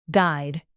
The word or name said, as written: guide